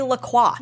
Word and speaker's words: La Croix: It is not LaCroix. I don't care what the fucking website says. – No, you just got you lost our sponsorship. We need to start awareness about the proper pronunciation of that drink. – Like, starting with the company itself. Because they tell people the wrong thing. – They say, and they're like, no, but that's what the website says.